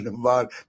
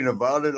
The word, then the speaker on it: Nevada: When I lived in Vegas for a little bit, it's Nevada. It's not Nevada. They get mad if you say it wrong. If you say Nevada, like, get your ass.